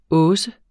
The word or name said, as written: hausse